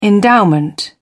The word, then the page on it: endowment: British English